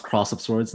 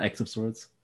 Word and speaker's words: X of Swords: You know it's actually pronounced Cross of Swords, not X of Swords? – Well, even worse.